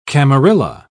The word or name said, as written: camarilla